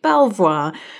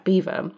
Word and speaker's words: Belvoir: Rather embarrassingly, you will hear me mispronounce the brand name throughout this episode because when speaking to Pev, I keep calling it Belvoir. It is in fact Beaver, and Pev was far too polite to really pick me up on it.